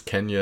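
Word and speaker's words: Quenya: Kenya, which is the Elvish language, and Goldegrin, which is the Gnomish Deep Elves, or later the Noldor. – I think you hit them on. I think it's Kenya and Goldegrin.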